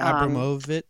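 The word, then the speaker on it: Abramović: It's Marina Abramovic. – Abramovic. Like there was some sort of collaboration amongst her and the Clintons, whereby there was like child sacrifice. – What? – And because that Marina Abramovic was mentioned in the John Podesta emails,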